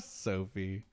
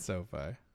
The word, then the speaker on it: SoFi: Did, I know with like the Sophie Stadium or SoFi Stadium, how you pronounce it. – Sophie. – Is it SoFi? – It's SoFi.